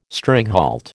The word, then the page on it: stringhalt: American English